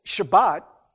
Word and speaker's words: Shabbat: But the Sabbath, Shabbat, as it's pronounced in Hebrew, actually means Saturday.